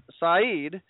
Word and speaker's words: Saeed: Fast forward to October 1983, Saeed pronounced Saeed found himself in the United States after fleeing his native country with little more than clothes on his back.